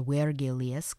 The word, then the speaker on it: Virgilius: So his full name is Publius Virgilius Maro, or Virgilius, because V's are W's, or they're pronounced as W's in Latin.